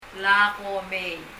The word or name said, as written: Lak komei